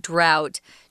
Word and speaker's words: drought: So, a drought, this is a weird word, guys. Um, it doesn't, it isn't spelled like we say it, drought, but that's how you say that word drought.